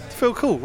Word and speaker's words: Phil Cool: Phil Cool rhymes with Red Bull.